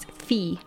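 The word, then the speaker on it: fille: And if you guys want a little French lesson, So when you see in French an I double L E, the sound is E. So the L isn't pronounced, so it's fee.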